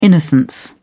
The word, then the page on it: innocence: British English